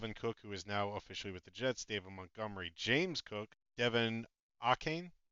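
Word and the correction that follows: De'Von Achane: I believe the proper pronunciation is Devon Achane. – Achane. It's these H's, man. These CHs that are giving me problems.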